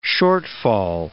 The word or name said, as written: shortfall